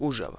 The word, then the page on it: Užava: Uzhava